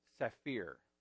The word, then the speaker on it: Saphir: by a 19th century Hungarian Jewish Christian named Adolf Safir, I think is how you pronounce the last name, S-A-P-H-I-R. Safir, yeah.